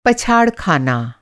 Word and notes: पछाड़: پچھاڑ

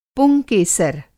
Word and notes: पुंकेसर: Hindi

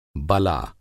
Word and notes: बला: Hindi

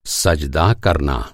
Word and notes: सज़दा: سجدہ

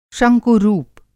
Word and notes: शंकु-रूप: Hindi

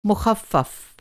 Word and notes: मुख़फ़्फ़फ़: مُخفّف

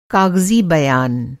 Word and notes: काग़ज़ी: Hindi

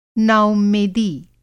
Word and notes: नाउम्मेदी: Hindi

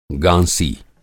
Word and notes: गाँसी: Hindi

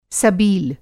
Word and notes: सबील: سبیل

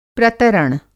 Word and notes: प्रतरण: Hindi

